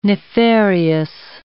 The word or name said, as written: nefarious